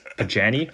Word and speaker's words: Pagani: So that's how you pronounce the brand. Oh, geez. I've been butchering it. – Pagani.